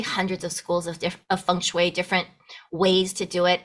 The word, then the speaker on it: feng shui: So feng shui is how you say it. And feng, which is what you call F-E-N-G, feng, is the wind.